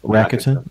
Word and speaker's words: Rakuten: Yeah, how do you pronounce it? – Rakuten.